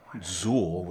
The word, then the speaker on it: Xul: Zuul, which is spelled X-U-L.